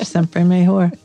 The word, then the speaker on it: siempre mejor: And it is siempre mejor.